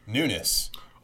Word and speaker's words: Nunes: So Chairman Nunes and listeners, we did look up the Congressional Quarterly Pronunciation Guide to make sure, and it is Nunes.